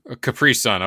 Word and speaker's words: Capri Sun: Capri Sun, okay.